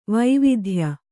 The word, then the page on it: ವೈವಿಧ್ಯ: vaividhya